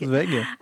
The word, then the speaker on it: Zweig: Zweig.